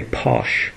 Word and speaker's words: Pausch: But Randy, his name was Randy, his last name is spelled P-A-U-S-C-H, so I'm going to say Posh. Randy Posh's last lecture would be different, because not only was he retiring, he had pancreatic cancer.